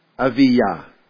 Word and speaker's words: Aviyah: Now, Abijah, I looked it up in the Hebrew, and I'm no good at pronouncing Hebrew, but in the Hebrew it's Aviyah. Aviyah, is that right?